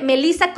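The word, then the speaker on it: Melissa: Bueno, pues, como la doctora Melisa Tejeda. Van a escribir mi nombre, Melisa con doble S y mi apellido Tejeda se escribe T de Tito, J de jamón y la letra D de dedo, Tejeda.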